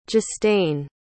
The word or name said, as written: justain